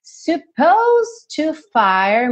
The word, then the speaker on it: supposed: Outra palavrinha terminada em T-I-O-N, ok? E ali no supposed to, duas coisas interessantes aí. Que esse é do supposed, não é pronunciado. Você não vai falar supposed, tá? Supposed. E esse D vai ficar praticamente imperceptível, porque você já vai juntar no to.